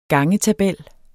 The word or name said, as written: gangetabel